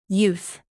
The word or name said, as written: youth